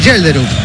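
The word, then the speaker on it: Schjelderup: Y entra el número 21, Yelderup. Vamos a decir Yelderup. – El mariscal Yelderup.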